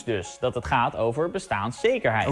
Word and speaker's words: bestaanszekerheid: Maar wat is nu de goede uitspraak? Nou, Het woord staat al 40 jaar in de grondwet en vroeger sprak iedereen het min of meer op dezelfde manier uit. Gewoon zoals de dikke vandalen ook aangeeft met de klemtoon op bestaan. Het is bestaanszekerheid. Eigenlijk helemaal zoals we dat met vergelijkbare woorden doen.